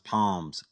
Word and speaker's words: Palms: "First of all, it's Palms. That's my name. You guys were getting it wrong all last night, and now I need to tell you it's pronounced Palms, like the trees."